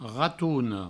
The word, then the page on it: ratona: ratouno